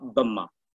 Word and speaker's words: Bamar: La mayoría etnia se llama Bama, Bama, con B-A-M-A-R. Se pronuncia Bama.